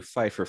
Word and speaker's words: Pfeiffer: Hello, everyone, and welcome to Pfeiffer Fridays, where we walk you through the films of one Michelle Pfeiffer, and every F-word automatically has a silent P.